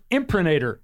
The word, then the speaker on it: imprimatur: And today's word of the day, here it is, friends and neighbors, imprimatur. – Okay, say that again. – Imprimatur. – What? – Imprimatur. Is that right? – No, it's not right. I think it's pronounced imprimatur.